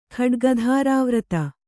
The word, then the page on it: ಖಡ್ಗಧಾರಾವ್ರತ: khaḍgadharāvrata